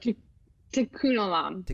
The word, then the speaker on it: Tikkun olam: Tikkun olam. Did I say that right? – Tikkun olam. Very close. Tikkun olam.